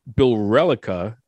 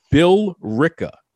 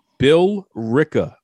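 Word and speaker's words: Billerica: She said Bill Relica or whatever the heck that was. It's Bill Ricca. Bill Ricca, Massachusetts. Okay. That's how it's pronounced. Bill Ricca.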